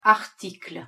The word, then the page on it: article: French